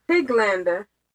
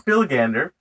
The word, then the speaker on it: Pilgander: Piglander. I guess I'm... – Really? – Yeah. – What Pilgander. – No, Pilgander. I said it right the first time. – Oh. – Piglander sounds horrible.